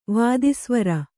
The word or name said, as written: ವಾದಿಸ್ವರ